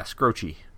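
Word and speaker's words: Skroce: Is that pronounced right? Scroce? I heard some people say. I'm not sure. – Yeah, Scroce.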